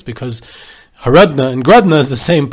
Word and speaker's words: Horodno: He was the son-in-law of another Grodno legend, Reb Nachumke of Horodno, which is Grodno in the Russian pronunciation, where they couldn't say the letter H, so H. Horodno became Grodno.